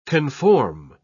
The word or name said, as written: conform